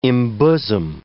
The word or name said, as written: embosom